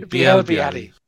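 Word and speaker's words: Bielle-Biarrey: And Billy Barry. Apologies, I study French. Even I know I'm pronouncing that wrong. – Belly Barry is the least French name of all time. Yeah. It's Biel Biarri, maybe. – Biel Biarri. Yes. Thank you very much.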